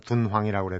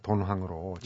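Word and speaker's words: 둔황이라고 (敦煌): 도낭학 이제 그냥 우리가 원래는 중국 발음은 둔황이라고 그래야 되는데 편하게 그냥 돈황으로 음.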